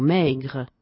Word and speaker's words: maigre: Quando la parola finisce con una E, questa è chiamata muta, poiché l'accento tonico si sposta sulla penultima sillaba. Maigre, maigre, ma diciamo maigre.